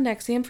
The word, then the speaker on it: NXIVM: Just a little background on Nexium for those of you that don't know what it is. It's spelled N-X-I-V-M.